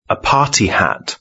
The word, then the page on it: a party hat: British English